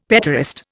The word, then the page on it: petaurist: Indian